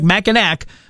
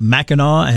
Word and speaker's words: Mackinac: It looks like Mackinac, but trust me, it's Mackinac, and they'll let you know if you say it wrong.